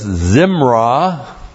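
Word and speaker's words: Zimrah (זמרה): There is a noun from this, Zimrah. Z-I-M-R-A-H, Zimrah.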